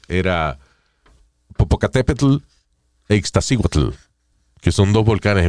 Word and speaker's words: Popocatépetl: Te el volcán Popocatépetl, porque es una it's a very special pronunciation. Tiene que decir Popocatepetl Popocatépetl sea, you have to do your tea like from the back of, of the.